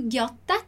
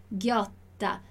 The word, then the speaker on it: ghiotta: Você já sabe aqui, le doppie consonanti. Então, como é que eu vou pronunciar aqui o ghiotta? Ghiotta. Dillo chiaro, ghiotta.